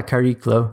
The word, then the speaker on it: Chariklo: Is it char- charicolo? – Yeah, Chariklo, like charisma.